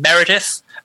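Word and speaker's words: Meredydd: As long as you know that a Y is pronounced I and a double D is pronounced th. So it's Meredith. If you're a native English speaker and you're trying to pronounce it, you just start with the name Meredith and then you think the word red loudly in the middle of it and you get there.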